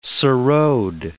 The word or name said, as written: sarode